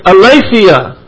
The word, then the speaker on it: aletheia: It's aletheia. A-L-E-T-H-E-I-A. That first E is a eta, which is a long E, and is pronounced as the A.